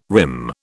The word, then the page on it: rim: British English